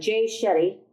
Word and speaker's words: Jay Shetty: And this morning on the drive here, I was listening to, is it Jay Shetty? – Jay Shetty.